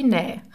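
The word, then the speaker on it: Nä (ναι): Und das griechische Wort für Ja wird ausgesprochen wie Nä.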